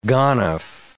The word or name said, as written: ganef